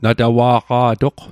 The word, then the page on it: natawa'qa'toq: Mi'gmaq